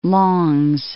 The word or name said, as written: Longs Peak